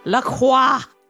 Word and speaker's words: La Croix: I just want everyone to know that I pronounce it La Croix, and that's the right way.